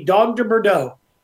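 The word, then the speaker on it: Dogue de Bordeaux: So I'm not a lot better with the pronunciation than you are, Sean, but it's the Dog de Bordeaux.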